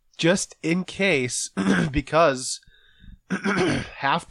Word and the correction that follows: Hafley: does play. Did I say his name right? – Hafley. – Hafley.